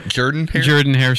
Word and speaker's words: Jordan-Hare: Jordan-Hare Stadium.